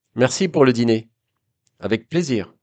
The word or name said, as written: avec plaisir